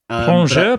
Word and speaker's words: plongeur: Ploplof. Plongeur professional. It's a contraction of two words. I think French. It's a French word, plongeur.